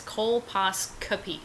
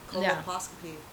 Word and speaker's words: colposcopy: It's colposcopy. – I've been saying it wrong. Colposcopy. Got it. – And then trying to spell it. Oh my God. Which is easy when you go col, C-O-L, pos, P-O-S, copy, K-O-P-Y, colposcopy. Fuck you.